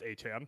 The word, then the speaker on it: Achane: Yeah, he says he came out this week on Twitter and was like, it's pronounced Achane. It's like, oh, well, you really waited to break out before telling everyone that, didn't you?